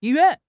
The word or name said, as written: yi1 yuan4